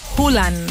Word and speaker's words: Haaland: Se trata de Haaland, o como descubrí que se pronuncia, Hulan.